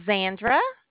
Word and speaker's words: Zandra: My name is Zandra.